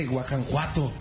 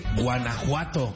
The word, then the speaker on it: Guanajuato: No se dice Guajanjuato, se dice Guanajuato.